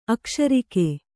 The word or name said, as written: ಅಕಶೇರುಕ